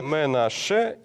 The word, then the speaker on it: Менаше: Менаше и Х не читается. – Да, Менаше и вот Х не считается.